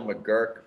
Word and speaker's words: McGuirk: I just call her Michelle McGuirk because at UMass, the football stadium is McGuirk Alumni Stadium, and it's spelled the exact same way.